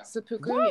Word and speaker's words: Seppuku: Yeah, like right before, because like when I was looking everything up, I was looking up like your music videos, like for like Seppuku, is that how you say it? – Yeah. – Seppuku. Yeah.